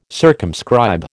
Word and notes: circumscribe: American English

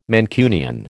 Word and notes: Mancunian: American English

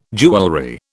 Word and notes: jewellery: British English

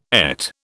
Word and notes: ET: British English

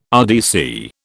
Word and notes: RDC: British English

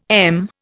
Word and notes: m: Indian

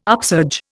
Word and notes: upsurge: Indian

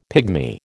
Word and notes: pigmy: American English

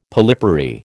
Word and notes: polypary: American English

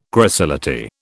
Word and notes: gracility: British English

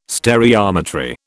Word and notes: stereometry: British English

